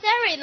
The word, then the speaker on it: ceremony: 庆典这单词哈，ceremony 哈，看这单词，ceremony 哈，看见后面那单词的发音有点像钱吧？money 对不对？感觉的。那么 ceremony 呢，发的音有点像三里的感觉，zero 哈，看记得。